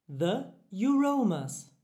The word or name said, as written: The Euroamers